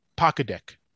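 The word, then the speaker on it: Pokdick: No C in poke, just P-O-K. So actually, so it's more like Pock. Pokedick. So Pokedick, thank you very much for that kind review.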